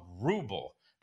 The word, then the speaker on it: ruble: It's called ruble. That's how you pronounce it, Joe Biden. Say it with me. Ruble.